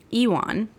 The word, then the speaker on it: Iwan: Okay, so Iwan, which when I was first listening, I was like, Iwan, surely she's not saying this correctly. Like, surely the narrator got this wrong. But then I looked it up, and it is Iwan. It is a Welsh name, and that is how it's pronounced.